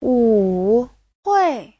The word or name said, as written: wu3hui4